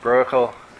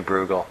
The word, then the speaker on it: Bruegel: Some people pronounce his name Bruegel. In Holland, they say Brokkel, but I say Bruegel.